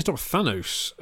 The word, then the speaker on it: Thanos: So there's a whole, why didn't you stop Thanos, as Dane says, which I that's how Americans pronounce Thanos. I've never heard a British person say Thanos, but there you go.